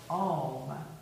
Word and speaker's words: Om: And we like to end our yoga practice by making the sound of Om.